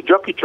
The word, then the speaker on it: Jokić: el poder de Nikola Jokić, no sé si lo pronuncie bien, el que le dicen el inmortal, ¿sí?, de los Denver Nuggets? – Sí, Jokić, otro jugador, un jugador serbio de gran, gran condición.